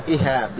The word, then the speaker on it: إهاب: طبعا الإهاب هذا هكذا تسمعونه نطقه الشيخ، هنا نقول إهاب بكسر أوله فتح الهاء، يعني مثل كتاب، يعني الجلد أو ما لم يدبغ، ما لم يدبغ من الجلد.